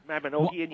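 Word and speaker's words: Mabinogion: And the Magdabinian. – Mabinogian, yes. – Thank you for saying it correctly.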